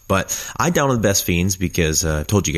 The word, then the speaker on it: Best Fiends: It's not Best Friends, it's like spelled like Best Friends, but without the R. Best Fiends. Best Fiends is a casual game anyone can play,